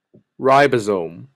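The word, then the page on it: ribosome: Canadian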